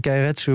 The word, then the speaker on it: Keiretsu: Daar heb je de, hoe zeg ik dat goed, de Keiretsu. – Ja, eh, Keiretsu eh, wil zeggen dat een bedrijf heel erg verweven is met eigenlijk haar financiers, met haar leveranciers.